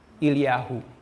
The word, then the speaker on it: Elihu: His name is Elihu. I'm not sure I'm pronounced it right. How are you? – Eliyahu. – Okay, sorry. I will keep using this, Eliyahu.